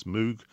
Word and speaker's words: Moog: Number two is from a synthesizer manufacturer called Moog, M-O-O-G. People often mispronounce it as Moog. It's named after a guy called Robert Moog, so I guess it's Moog.